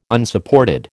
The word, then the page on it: Unsupported: American English